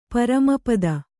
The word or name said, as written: ಪರಮಪದ